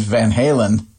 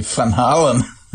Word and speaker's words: Van Halen: There develops this thing where the person being addressed gradually adopts the mispronunciation as a nickname, like which happened to the band Van Halen, whose name is actually Van Halen. And I think that's kind of incredible. Like, their entire career is based on an Americanized mispronunciation of a Dutch surname.